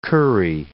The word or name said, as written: curry